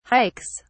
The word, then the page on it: hikes: British English